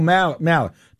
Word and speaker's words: Mahle: Like rally steak. Oh, Mahle. Tyler Mahle. Just like draft picks.